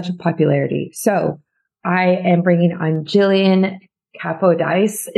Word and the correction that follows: Capodice: You have to say it with an Italian flair. It's Capodice. – Capodice.